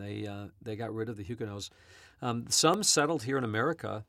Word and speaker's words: Huguenot: She was a French Huguenot. Others might know them as French Huguenots, but that's not the right pronunciation. Huguenots. – We say Huguenot.